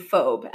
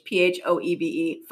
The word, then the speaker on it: Phoebe: And I pronounced Phoebe Phobe. And my mom's like, I'm pretty sure it's Phoebe. And I'm like, no, I'm looking at it on the page. Phoebe, Phobe.